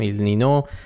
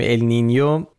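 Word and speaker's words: El Niño: به هر حال اینو همین اول بگیم که اگه دیدید یه بار میگیم ال نینو یه بار میگیم ال نینیو، علتش اینه، ولی تلفظ درست اگه میخواین ال نینیوه، ولی همه جا تو فارسی به چرخید کسی چی نمیفته ال نینیو، همه جا نوشتن ال نینو.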